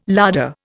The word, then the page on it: larder: Indian